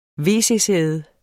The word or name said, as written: wc-sæde